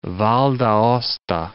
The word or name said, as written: Val d'Aosta